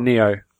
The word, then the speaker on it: NieR: Is it Nier? I'm never sure. – It's Neo. – Neo, okay. Because I wish...